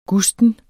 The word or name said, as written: gusten